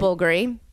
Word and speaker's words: Bulgari: Bulgari.